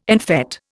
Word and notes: en fête: Indian

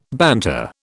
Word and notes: banter: British English

